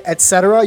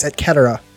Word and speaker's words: et cetera: C's are a hard consonant. Mm-hmm. Consonant, whatever. So when you say et cetera, you're fucking wrong. It's et cetera. – I didn't even notice. – Vinny, Vidi, Vici. Okay? – Okay. – It's a hard C. Always a hard C.